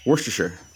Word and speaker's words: Worcestershire: But how the heck do you pronounce Is it Worcestershire sauce? You you just say it fast. You just say Worcestershire.